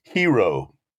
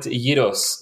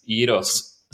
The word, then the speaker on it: gyros: Is it hero? – In Greek, if you're Greek, you call it gyros. So that's how we say it in Greece, gyros. Gyros, okay.